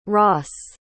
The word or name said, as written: ross